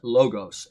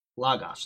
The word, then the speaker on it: logos: Like a lot of people say the word logos, they pronounce it logos. It's not logos, it's logos. Those are omicrons there.